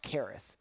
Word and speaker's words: Cherith: Spelled Cherith, pronounced Kereth, lived by the brook Kereth that is east of the Jordan.